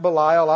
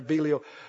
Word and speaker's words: Belial: Some people pronounce it Belial, I know, but the ones I've read it after, Belial.